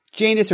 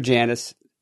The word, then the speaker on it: Janus: Janus or Janus, both are good. One's British pronunciation, one's American.